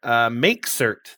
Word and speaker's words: mkcert: We're talking about MakeCert this week, which is how I believe you pronounce it, but it's spelled MKCERT.